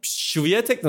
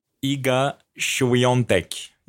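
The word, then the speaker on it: Świątek: Yani demek biraz Peki Şviyetek nasıl okunuyor? Roland Garros gündemini yakından takip ediyorsun. Iga Şviyontek. Yani futbol severler aslında o harfi biraz Piontek'ten e, yakaladılar birkaç yıl önce. Milan transferine çok konuşulmuş. Piatek diye yazılıp Piontek diye okunduğu için e, Polonyalı forvet.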